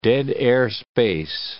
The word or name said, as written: dead-air space